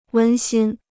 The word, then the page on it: 温馨: wēn xīn